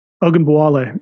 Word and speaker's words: Ogunbowale: How do you say the best player on the Wings last name? It's like Ogunbowale. Is that how you say it? – Oh, Arika. Yeah. Ogunbowale. Yeah.